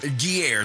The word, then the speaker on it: Guerre: Guerre.